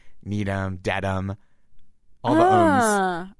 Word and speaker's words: Needham: I was thinking Needham. – Yep, that was what I thought. – Because it ends with H-A-M, but mm-hmm. it's pronounced M.